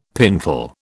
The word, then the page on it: pintle: British English